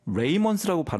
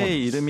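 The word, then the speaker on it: Ramones: 그래서 76년에 오늘 소개하는 이 보통 이제 RAMONES라서 레이먼스라고 발음하시는 분들이 종종 계신데 본래의 라몬스. – 이름이 라몬스고요.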